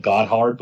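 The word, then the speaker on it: Gotthard: And the way you spell it is G-O-T-T-H-A-R-D. I guess you could even call it Goddard, but that's not how they pronounce it.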